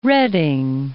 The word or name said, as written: Redding